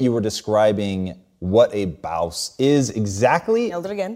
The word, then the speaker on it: Bawse: so your book, How to Be a Bawse.